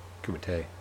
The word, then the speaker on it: Kumite: Trying to find out what's happening with this Kumita. Like, where's that? Did I say it wrong? – Yeah. You're going to keep saying it wrong, so. – Kumite? – Kumite.